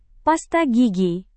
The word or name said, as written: pasta gigi